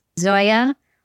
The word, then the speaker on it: Xolla: I guess it's pronounced Zoya Wool. So X-O-L-L-A.